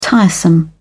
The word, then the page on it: tiresome: British English